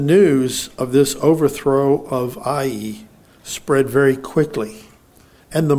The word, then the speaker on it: Ai: But I don't know exactly how it got translated or transliterated into English as AI. But, well, that, anyway, has nothing to do with the story, but I just thought it was interesting. But the name of the town is pronounced Ai or Aya.